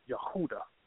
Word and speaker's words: Yehuda: There are no J's in the Hebrew or the Greek. They're pronounced as Y. So the tribe of Judah is really pronounced Yehuda, and that's what his name is.